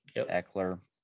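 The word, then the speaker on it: Ekeler: How do we say his last name? – Eckler.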